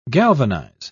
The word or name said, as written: galvanize